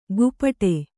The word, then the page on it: ಗುಪಟೆ: gupaṭe